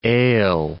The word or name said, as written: ail